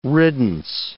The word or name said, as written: riddance